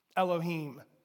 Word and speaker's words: Elohim: And I am not a scholar in Greek or Hebrew, but there are apps that tell me how to pronounce them. This one's easy. Elohim.